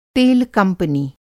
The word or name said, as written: तेल कंपनी